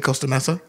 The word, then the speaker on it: Costa Mesa: I mean, I was going to say Costa Mesa.